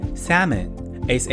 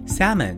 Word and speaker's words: salmon: 鲑鱼 salmon，s a l m o n salmon l 不发音。